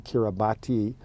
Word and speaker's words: Kiribati: And it's spelled K-I-R-I-B-A-T-I. It looks like Kiribati, but it's actually pronounced Kiribati. And the reason it's that way is because when the missionaries came, they had one typewriter, and the typewriter didn't have an S.